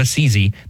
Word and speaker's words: Assisi: It's don't say a sissy. You know, Saint Francis was not a sissy. Say St. Francis of Assisi. That's how you pronounce it, because you don't want to ever imply that he was some kind of a sissy.